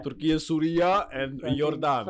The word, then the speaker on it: Syria: How do you pronounce Syria the right way? – Syria. – Isn't it Surya or something like that? – Surya.